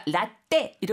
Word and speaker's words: latte: 그러니까 라떼 이러면 좀 잘 못 알아듣는 경우가 있는 것 같아요. – 영어로는 T가 두 개 있어도 따가 아니고 네. 라떼 발음을 많이 하더라고요. 라떼.